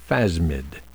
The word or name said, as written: phasmid